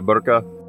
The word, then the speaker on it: burqa: is that how you pronounce it? Uh, yeah, hijab, uh, burqa.